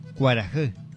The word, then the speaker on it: Kuarahi: La palabra cuarajé, ¿no? Esa es la pronunciación, cuarajé. Se escribe de esta manera. K-U-A-R-A-H-I.